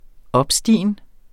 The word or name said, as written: opstigen